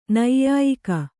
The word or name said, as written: ನ್ಯಾಯಿಗ